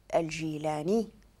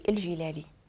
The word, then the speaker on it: الجيلاني: الاصل ديالو الجيلاني نسبة لسيدي عبد القادر الجيلاني اللي تزاد في المنطقة ديال جيلان، ماشي الجيلالي، الجيلاني، ولكن حنا في المغرب مشينا كنطقوها دائما شوية كننطقوها شوية غلطة.